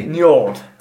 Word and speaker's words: Njord: Can you spell that? That's not, obviously not correct. – N-J-O-R-D. – Njord. – Njord. Of the Vanir. – Sounds like you're saying Nerd.